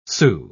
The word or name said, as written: sou